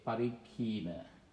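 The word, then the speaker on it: parikkhīṇa: That's N-A-N-D-I, long I, Nandi, dash, B-H-A-V-A, Bhava, and Parikhina, P-A-R-I-K-K-H-I, long I, so pronounced longer, N-A, Parikhina.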